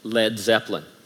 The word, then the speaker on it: Led Zeppelin: Inspired by Moon, Page and company dropped the A in lead in order to avoid confusion with the pronunciation, and they switched balloon for Zeppelin, probably because it just is cooler, and hence the name Led Zeppelin.